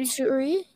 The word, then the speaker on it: Mitsuri: Mitsuri, is how you say it.